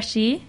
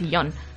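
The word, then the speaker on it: shi (四): Bueno, es que 4 eh, se pronuncia shi y shi es muerte, así es. – claro, y por eso hay una pronunciación alternativa que es yon El sí.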